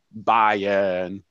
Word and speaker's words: Bayern: And PSG also lose to Bayern, but again, on the aways, 3-3 aggregate, they are going through. Oh, so sorry. The German speaker is correcting me. One more time, please. Bayern.